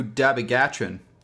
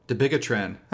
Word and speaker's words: dabigatran: Let's move on to dabigatran, or dabigatran, however you'd like to pronounce it.